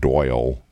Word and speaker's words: Doyle: You'll find it at Doyle King on Facebook. My first name is spelled D-O-Y-L-E.